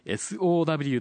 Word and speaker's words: SOW: ありがとうございました。はい、ライトノベル作家の SOW と書いて SO さんにご出演いただきました。